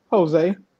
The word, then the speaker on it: Hosea: But it's spelled with an H instead of a J. – Your last name is Jose? – Yeah. Well, it depends on what part of the world you come from. – It's spelled like the book in the Bible, Hosea.